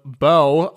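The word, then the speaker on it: Børge: Ja, eure Königin heißt auch, immer wenn Sören, mein Kumpel von der Königin Makrele spricht, der Name meines, Makrele, der Name meines Großvaters wird B-O-R-G-E geschrieben. – Borge? – Und wird Bo ausgesprochen. – Bo.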